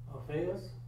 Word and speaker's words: Alphaeus: I can't pronounce that, what is that? – Alphaeus, and Thaddeus, Judas, not Iscariot.